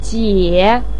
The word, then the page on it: jie3: pinyin